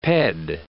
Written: ped

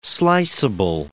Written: sliceable

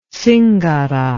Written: zingara

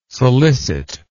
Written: solicit

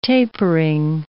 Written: tapering

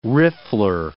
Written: riffler

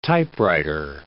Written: typewriter

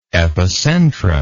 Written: epicentrum

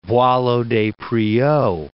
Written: Boileau-Despreaux

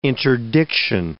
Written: interdiction

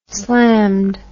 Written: slammed

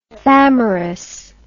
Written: thamyris

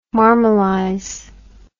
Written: marmalize

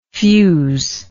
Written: fuselike

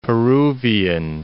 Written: Peruvian

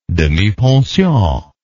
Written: demipension

